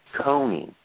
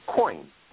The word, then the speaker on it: Kony: Now, unknown to most people in this whole saga is that who is commonly called Joseph Coney, K-O-N-Y, it's not pronounced Coney. In the Acholi language, it's pronounced coin. There's no Y sound. It's coin.